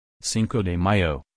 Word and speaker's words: Cinco de Mayo: Cinco de Mayo, pronounced Cinco de Mayo,